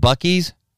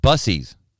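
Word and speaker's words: Buc-ee's: She mispronounced the name. – Okay. – Instead of calling it Bucky's, she called it Bussies.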